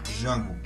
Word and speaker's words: Django: Django, porque o D é mudo.